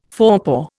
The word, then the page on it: forepaw: Indian